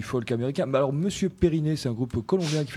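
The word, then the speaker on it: Periné: La chanteuse a fait des études au lycée français, donc j'imagine elle le prononce avec l'accent français. – Donc Périné.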